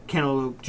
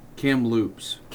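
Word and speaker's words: Kamloops: Yeah, because this year's Memorial Cup of 2023, the Cantaloupe Giants of the WHL, they're going to be the hosts.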